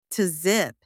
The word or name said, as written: to zip